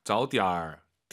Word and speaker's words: 早点儿: 早点儿, 点儿 올라가면 안 되죠. 뒤에가 이성이니까 반삼성.